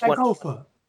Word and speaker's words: Sankofa: And yes, I just Sankofa.